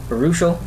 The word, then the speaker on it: Baruchel: Or how do you say his name? – Jay Baruchel. Baruchel.